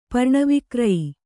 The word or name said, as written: ಪರ್ಣವಿಕ್ರಯಿ